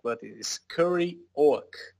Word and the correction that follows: Scurry Oak: Oh, Scurry Oak.